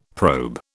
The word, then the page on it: probe: British English